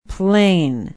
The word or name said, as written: Plain